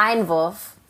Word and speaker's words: Einwurf: Like wait, how do you say it though? Einwurf? – What is it? Einwurf. Einwurf.